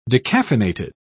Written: decaffeinated

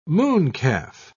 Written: mooncalf

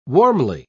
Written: warmly

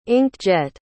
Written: ink-jet

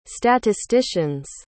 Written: statisticians

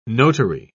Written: notary

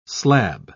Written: slab

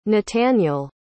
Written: nataniel

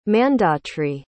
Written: mandhatri

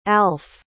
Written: alf

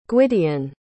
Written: gwydion

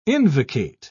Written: invocate

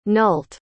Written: nulte

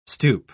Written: stupe

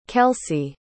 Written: kelsi

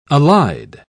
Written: allied

